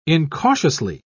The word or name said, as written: incautiously